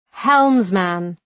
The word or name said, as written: helmsman